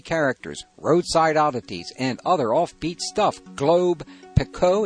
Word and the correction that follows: Pequot: is that how you pronounce that, Pico? Pequot, sorry, Pequot Press.